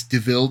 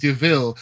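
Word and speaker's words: De Vil: Like, you know, the car scene where she gets the infamous DeVille name. – And they're like, oh, it's Devil, but it's pronounced DeVille.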